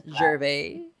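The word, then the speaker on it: Gervaise: Silent S, Gervais. – I, definitely, it's the uh, it's the French.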